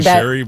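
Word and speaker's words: Chérie: It's actually French, and I think because it's French, you Cherie is probably the way to say it. I'll say Cherie, but you know, I mean, that's the cool thing.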